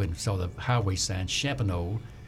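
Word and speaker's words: Champagnolle: It's called Champagnole, and it's C-H-A-M-P-A-G-N-O-L-L-E.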